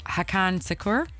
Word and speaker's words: Hakan Şükür: Okay, how about in 2002, we're talking about, I'm going to say his name wrong, Hakan Şükür, is that how you say his name? – Yeah, pretty close.